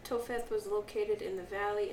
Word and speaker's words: Topheth: How do you say that? Topheth. P and an H becomes an F sound, right? – In English.